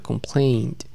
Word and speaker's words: complained: Repeat after me, complained. Como con una T al final, complained.